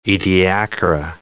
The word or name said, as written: Ediacara